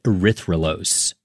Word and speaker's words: erythrolose: erythrolose.